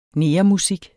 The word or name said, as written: negermusik